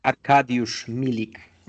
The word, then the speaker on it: Arkadiusz Milik: Arkadiusz Milik. Arkadiusz, what a name. What a strong, beautiful name that should be in a Roma kit. And Arkadiusz Milik.